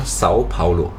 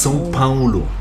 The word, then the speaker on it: São Paulo: Vielleicht auch noch ganz interessant, weil das, wir sagen ja mal bei uns im Deutschen São Paulo, na, als Stadt. Aber ausgesprochen wird es São Paulo. – Ja, das genau. – Das ist sehr nasal.